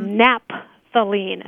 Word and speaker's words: naphthalene: Now, one of the questions our students often have is how you pronounce that, because sometimes people just abbreviate the PH as a P. – Mm-hmm. – So they would mm-hmm. – say naphthalene as mm-hmm.